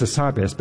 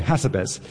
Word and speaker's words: Hassabis: I think I'm right, it's Demis Hassabis, but they say Hassabis. – Yes. – And I say, no, no, you have to put emphasis on the right syllable.